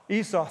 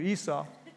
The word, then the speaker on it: Esau: One of the stories that really gets my attention is the story of Esau. And I don't know if I say his name right. And Jacob. Esau? Esau, thank you, Esau.